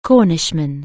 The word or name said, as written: Cornishman